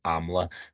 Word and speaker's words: AMLA: Grant, moving on then to AMLA or AMLA. I've listened to some interviews and I'm reliably informed it's going to be pronounced AMLA.